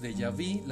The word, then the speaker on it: déjà vu: Los déjà vu, la pronunciación correcta es déjà vu, viene del francés déjà vu,